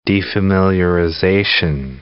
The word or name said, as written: defamiliarization